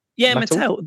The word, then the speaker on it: Mattel: Mattel, M-A-T-T-E-L, Mattel. I don't know how to say it. Mattel? – Yeah, Mattel.